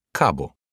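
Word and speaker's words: кабу (かぶ): По-японски слово «акция» произносится так же, как и слово «редька» — «кабу».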